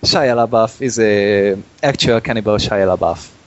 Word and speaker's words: Shia LaBeouf: ez a Shia Buff. – Shia LaBeouf. – Shia, Shia LaBeouf.